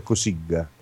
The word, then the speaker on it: Cossiga: Poi Cossiga si pronuncia Cossiga, ricordati sempre che...